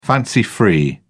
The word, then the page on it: fancy free: British English